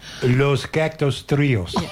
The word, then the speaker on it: Los Cactus Trios: It's going to be the Sourdoughs, supported by Los Cactus Trios. I didn't say that properly, did I? Come on. Tell me how to say it. – Los Cactus Trios. – That is excellent.